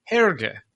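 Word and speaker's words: Hergé: Remy is better known by his pen name, Herge, which he derived from the pronunciation of his transposed initials, RG, as it is pronounced in French.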